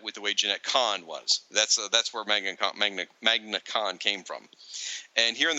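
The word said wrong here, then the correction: Manga Khan: the cover, Manga Kahn. – I keep saying it wrong, don't I? – Manga.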